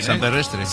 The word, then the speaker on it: San Perrestre: San Perrestre. – Sí, no San Pedrestre, la San Perrestre.